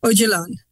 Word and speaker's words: Öcalan: This is Ocalan. – Öcalan.